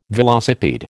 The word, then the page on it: velocipede: American English